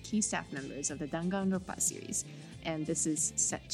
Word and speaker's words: Danganronpa: Danganronpa? Yeah, I think that's how you say it. – Danganronpa.